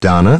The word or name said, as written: Donna